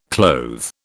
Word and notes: clothe: British English

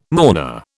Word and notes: mourner: British English

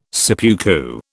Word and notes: seppuku: British English

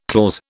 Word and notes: clause: British English

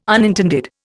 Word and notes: unintended: Indian

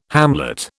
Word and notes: hamlet: British English

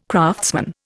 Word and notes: craftsman: Indian

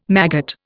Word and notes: maggot: Indian

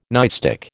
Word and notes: nightstick: American English

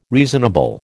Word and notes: reasonable: American English

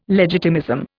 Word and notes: legitimism: Indian

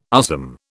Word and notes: USM: British English

